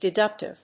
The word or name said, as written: deductive